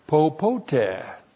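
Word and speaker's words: popote (πώποτε): And then that verb theaomai is modified by the adverb popote. That's P long O, P O T E, popote.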